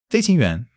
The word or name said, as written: 飞行员